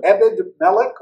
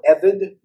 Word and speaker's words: Ebed-Melech: We meet a character we've never met before, Ebed-Melech, or Evid. You could say Ebed-Melech. The Hebrew can be pronounced that way.